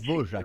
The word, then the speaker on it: Dvořák: o R fica meio J ali, né, maestro? – Isso, exatamente. – Dvorak. Exatamente.